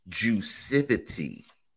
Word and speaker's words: Juicivity: And it's pronounced Juicivity.